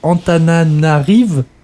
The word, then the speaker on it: Antananarivo: La capitale, Antananarivo, c'est ça ? – C'est ça, oui. – Le O ne se prononce pas.